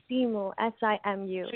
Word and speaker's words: Simu: This is Sima. I'm so sorry. And how do you pronounce your name? I want to make sure I say it correctly. – Hi, it's actually Simu, S-I-M-U.